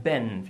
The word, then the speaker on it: been: I've been, fijaros la pronunciación del B-E-N, que siempre pronunciamos been, es un poco más E, ¿vale? Más E.